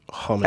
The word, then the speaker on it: Hamadeus: So space pig, all one word, Amadeus, as in Amadeus with an H. Space Pig Hamadeus. – As in ham.